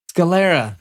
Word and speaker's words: Scalera: Let me hear you say his last name. Scarella? – Scalera. – Scalera. Thank you, sir.